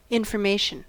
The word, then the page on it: information: American English